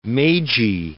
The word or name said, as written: Meiji